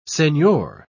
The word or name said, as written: senor